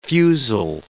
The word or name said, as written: fusil